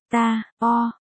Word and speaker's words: Tarot: Tarot, phát âm tiếng Pháp, ta, o, đầu tiên được gọi là Triumphi, sau đó là Tarochi, Tarot và V, V, là một bộ bài.